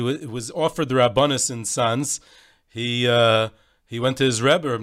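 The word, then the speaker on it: Sanz: in Polish, the name of Tzanz is Novi Satz, New Tzanz. So it's S, Tzanz. And in Yiddish, it's pronounced Tzanz. So he said to him,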